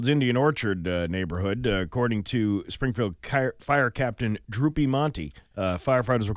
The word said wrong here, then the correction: Drew Piemonte: Steve, I believe it's pronounced Drew Piemonte.